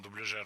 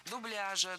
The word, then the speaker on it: дубляж: Дело в том, что совсем недавно за этим словом было закреплено неподвижное ударение на втором слоге. Дубляжа, дубляжа, дубляжем. Но так никто не говорил. И филологи сжалились, ввели двойную норму в этом слове. Теперь оба варианта, и дубляжа, и дубляжа, являются литературными.